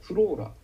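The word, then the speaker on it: flora: それとフローラ、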